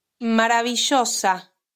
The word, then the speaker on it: maravillosa: The double L and Y are often pronounced with a J sound. So instead of maravillosa, you'll hear maravillosa.